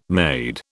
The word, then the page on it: made: British English